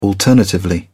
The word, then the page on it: alternatively: British English